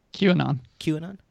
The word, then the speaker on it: QAnon: QAnon.